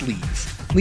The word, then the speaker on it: Liev: Some people are putting a little emphasis, like L E E, and then E-V. Eve, like, but it's Leave. Leave Schreiber. – But it's spelled L I E V. – Yes. Another people are saying just like Kiev, but with an L instead of the K.